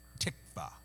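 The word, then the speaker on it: tikva (תקווה): The word hope in Hebrew is pronounced tikva.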